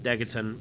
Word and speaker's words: Degaton: And uh, we're told that Degaton rhymes with Megaton, so hmm?